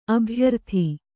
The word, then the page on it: ಅಭ್ಯರ್ಥಿ: abhyarthi